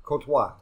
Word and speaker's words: Courtois: How do you say Courtois.